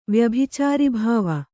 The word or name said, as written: ವ್ಯಭಿಚಾರಿಭಾವ